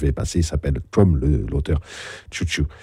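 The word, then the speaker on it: Sun Tzu: Enfin, on le prononce en France Tzu Tzu, enfin, la plupart du temps. Les Chinois le prononcent Chou Chou.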